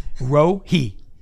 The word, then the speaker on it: Rohi: Rohi. R-O-H-I. And it says pronounced R-O-H-E-E. So he, like he haul, baby. Like, I mean, like there's no doubt there.